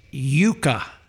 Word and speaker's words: Jyka: It's spelled J-Y-K-A. – Now, y'all heard that. – J-Y-K-A. Now, it's pronounced Yuka.